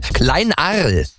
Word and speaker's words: Kleinarl: Heißt, habe ich das richtig ausgesprochen? Kleinarl. – Ja, Lars, das ist richtig.